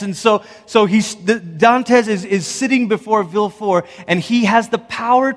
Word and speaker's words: Villefort: his name is spelt like it's Villefort, but I think the French pronunciation is Villefort. Anybody? Yep.